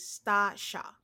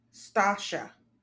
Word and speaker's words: Stasha: Her name is Stasha. – Stasha. – Yep. Two Stasha. Syllables. Sta and Sha.